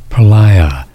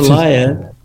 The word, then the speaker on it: Pralaya: Pronounce it for me. – No, the yoga. – Pralaya. – And that's Pralaya. You know